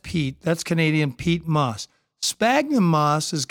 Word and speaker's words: Sphagnum: So do you want to touch on, and I'm going to mispronounce this, what is it, Sphagnum. – Sphagnum. – Yes.